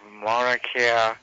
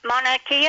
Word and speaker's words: Mauna Kea: Well, it says snow can, and there are the Mauna Kea. Am I pronouncing that correctly? – Yeah.